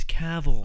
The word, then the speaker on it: Cavill: and that's going to be Henry Cavill. Is that how you say it? – It's Cavill.